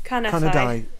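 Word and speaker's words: Carneddau: Carnedai. It depends, yeah, what part of Wales, but in well, Carnedai, it's a sound. – Which is I know... – But it's spelt like Carnedu.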